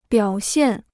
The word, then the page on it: 表现: biǎo xiàn